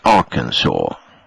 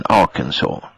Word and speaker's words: Arkansas: Den delstaten, det stavas så som du uttalade, men det uttalas Arkansas. – Jaha, det visste jag inte. – Nej, ja, det heter Kansas, Kansas, men Arkansas.